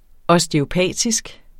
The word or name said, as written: osteopatisk